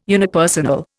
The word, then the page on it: unipersonal: Indian